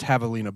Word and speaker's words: javelina: We've never talked about – and it is pronounced javelina, by the way.